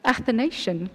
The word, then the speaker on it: Athanasian: Athanasian. What is it? – Athanasian Creed.